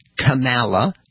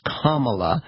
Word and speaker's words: Kamala: and it is not Kamala, and it is not Kamala, it's Kamala. That's right, we're all instructed, rhymes with Mamala.